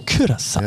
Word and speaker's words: Curaçao: Curaçao. – Nämlich nicht Curaçao, sondern Curaçao.